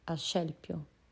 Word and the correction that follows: Asclepio: Asclepio, sono abbastanza sicura, o Esculapio, o Esculato, non so, Esculapio.